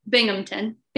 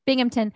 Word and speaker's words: Binghamton: How do I say it? Binghamton? – Binghamton. – Binghamton.